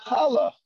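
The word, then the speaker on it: challah (חַלָּה): There you go, challah. Challah, right? That's how we pronounce it. Challah. Chet, lamed, hey. Again, hey is silent.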